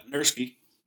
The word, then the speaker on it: Nurski: Yeah, Nurski.